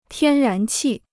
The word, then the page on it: 天然气: tiān rán qì